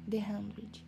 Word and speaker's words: The 100: Vim através desse podcast falar um pouco sobre a série The 100, que se pronuncia The Hundred.